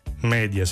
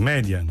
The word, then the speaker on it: media: Perciò plus si pronuncia plus, non plus. Media si dice media, non media. Junior si pronuncia junior, non junior. Se il fidanzato insiste affinché diciate junior, non cambiate pronuncia, cambiate fidanzato.